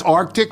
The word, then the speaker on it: RTIC: You're the Arctic cooler guy? – Oh, that's Arctic? That's how it's said.